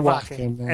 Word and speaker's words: Wacken: Per 21 ottobre, per chi è appassionato, esce un live di Alice Cooper registrato, non so mai come si pronuncia, Wacken, non so come la pronuncia corretta. – Bella domanda. Aspetta, sto festival dov'è esattamente? – È in Germania, credo, Wacken. – Quindi io propenderei più per Wacken. – Esatto, sì, esattamente. No,